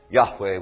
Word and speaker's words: Yahweh: What theologians call the tetragrammaton, what we sometimes pronounce Yahweh, which is probably the closest we can get to how it was originally pronounced.